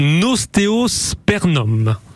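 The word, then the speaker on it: Osteospermum: je vous partage mon ostéospernum.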